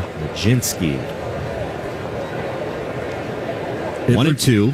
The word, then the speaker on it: Mlodzinski: Majinski. – How do you spell that? – M-L-O-D-Z-I-N-S-K-I. Majinski. Country of origin? – One, and it's a called strike two.